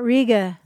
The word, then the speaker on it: Riga: Do you know how you pronounce the town that's spelled R-I-G-A, like the city in Latvia? – It's not Riga? – It's not Riga.